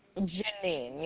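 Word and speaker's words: Jeannine: Janine? – Nice. – Janine, yep.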